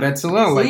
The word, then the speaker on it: Bezalel: Yeah, Bezalel.